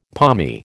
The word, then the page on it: palmy: American English